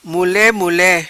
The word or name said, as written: Mule Mule